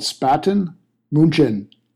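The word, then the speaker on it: Spaten München: It's called Spaten. Munchen. Is that right? – That's how I would pronounce it, yes.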